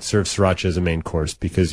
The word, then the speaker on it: Sriracha: Sriracha. – Well, it's a sauce that everybody says wrong because it should be sriracha because there's an R in there. – S-R-I-R-A-C-H-A.